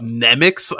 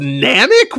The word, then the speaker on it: Namek: Nemics. I was like, what the fuck is a Nemic? And then I looked at the subtitles, that's how they pronounce Namek.